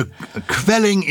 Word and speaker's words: kvelling: And I think it might come from there, but because Q-U-E-L-L-E-N in German is pronounced kvelling, as kvell became, is, yes, indeed, it does come from there. And we couldn't pronounce it, so we decided K-V-E-L-L would be a little bit more self-explanatory, which I agree with.